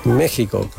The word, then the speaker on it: México: Entonces, vamos a recopilar que es México con J o con X escrito, preferentemente para dar gusto a los mexicanos con X, pero la pronunciación siempre con J, incluso que sea cantando.